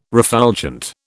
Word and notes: refulgent: British English

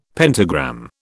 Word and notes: pentagram: British English

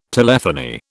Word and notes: telephony: British English

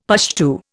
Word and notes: Pushtu: Indian

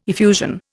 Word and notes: effusion: Indian

